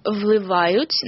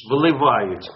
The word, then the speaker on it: впливають: Зміни у повсякденному житті позитивно впливають на... – Ні, от видиш, впливають. – Да. – А там не L, впливають, там V.